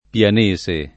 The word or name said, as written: pianese